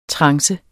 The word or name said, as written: trance